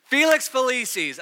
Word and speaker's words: Felix Felicis: But Harry gets given this potion, and it's called, I had to ask a Harry Potter expert, Felix Felicius? No, okay, say it again. Felix Felicis, okay, well, I feel like that's sort of a pronunciation thing, but whatever.